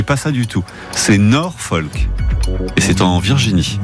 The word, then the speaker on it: Norfolk: Mais vous savez même pas le prononcer. Mais oui, mais bien sûr, Sébastien, bravo à vous. C'est Norfolk.